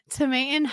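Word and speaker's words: Tomatin: We are drinking Tomaten Highland Scotch 12-year. – Is that how you say it? Tomaten? – Tomaten. Yeah. – I said it Tomaten. – Tomaten. Tomate. It's the long A.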